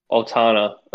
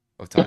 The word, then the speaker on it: Otani: And then you see Otana or Oni, how do you say it again? I keep forgetting. – Otani.